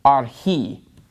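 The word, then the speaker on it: arhi (ἀρχῇ): So the accent is on the last syllable in this word, arhi.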